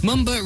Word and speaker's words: Mumba: Mumba rhymes with lumber, which is a term given to the lower region of the back.